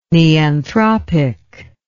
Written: neanthropic

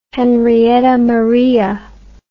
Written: Henrietta Maria

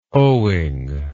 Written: owing

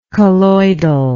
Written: colloidally

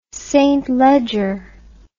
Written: St Leger

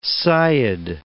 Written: sayyid

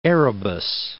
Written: Erebus, Mount

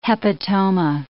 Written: hepatoma

